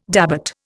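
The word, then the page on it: début: Indian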